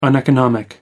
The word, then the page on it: uneconomic: American English